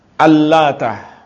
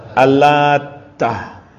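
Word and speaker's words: Al-Lata (اللات): Al-Lata ini ada dua bacaan. Mayoritas ulama membaca Al-Lata taknya tanpa tasydid. Ibnu Abbas, Ibnu Zubair, Mujahid dan yang lainnya membaca dengan ditasydid. Yaitu bagaimana bacanya kalau ditasydid? Al-Lata. Jadi taknya ditasydid.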